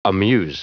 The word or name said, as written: amuse